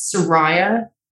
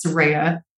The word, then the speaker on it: Saraya: And so everyone just assumes the American way of saying my name is Saraya because of the way you guys pronounce that R-A-Y. – Yeah. – But the way I like to pronounce it is Saraya, like a ray of sunshine.